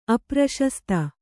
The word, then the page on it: ಅಪ್ರಶಸ್ತ: apraśasta